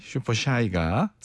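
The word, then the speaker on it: Super Shy: 그리고 빌보드 200 차트 1위까지 또 거머쥐었던 뉴진스의 슈퍼사이가 죄송해요. 발음이 너무 저질이었죠. 네. 아, 부끄럽네요. 베리 슈퍼샤이하네요. 예. 슈퍼샤이 슈퍼샤이가 사이가 아니라 슈퍼사이 슈퍼샤이가 많은 분들의 사랑을 받았습니다.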